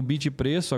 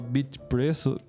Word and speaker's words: Bitypreço: É, tem que falar o Y, tá? Não é bit preço. O Y não é mudo.